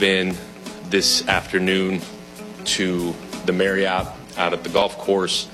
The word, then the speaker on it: Marriott: I always thought it was Marriott until we had to read their name. – Marriott. – Apparently it's Marriott.